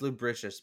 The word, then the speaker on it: lubricious: Lubricus the Spartan. Lubricus. – Like, nobody could touch him. Nobody could bring him down. He was so slippery. – Licentious. – It's pronounced lubricious, by the way.